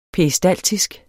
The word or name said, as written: peristaltisk